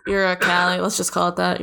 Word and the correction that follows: Uralkali: You said it's so like Southern American. Uracali. Uralkali. Yeah, the Russian petrochemical giant.